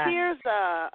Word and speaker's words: Teresa: Oh, it's Teresa. You know, picture hovering your fingers over your face like tears are running down, and my name is Teresa. – Oh, Teresa.